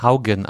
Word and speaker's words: Haugen: Francis Hugen. Äh, Haugen geschrieben. – Haugen. Äh, Franz Francis Haugen. Aber sie spricht sich selbst auch Haugen aus.